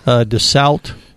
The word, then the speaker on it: Dassault: DASALT is how you say it if you read it. – Okay. – If you're French, you're DASALT.